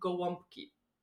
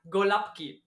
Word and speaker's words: Gołąbki: sind Gołąbki. – Klar. – Mein Mann sagt immer Golapki, weil es so geschrieben wird, wird halt anders ausgesprochen.